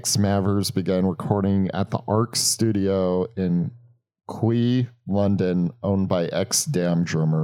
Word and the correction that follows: Kew: Wait, Quay? Spell that. – K-E-W? – Oh, you mean Q? – No, K. – K-E-W? – Yeah. – Q. No, that spells Q. K-E-W?